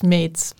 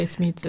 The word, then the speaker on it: Smeets: Yes, in Dutch it's Smeets, but you can say Smeets, it's easier.